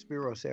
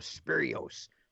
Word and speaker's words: Spiros: Sometimes he couldn't pronounce Spiros Arion, and he called him Spirios.